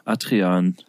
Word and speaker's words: Adrian: Adrian habe ich in der, in der Kita immer Atrian. gehört. – Adrian.